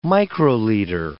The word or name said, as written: microliter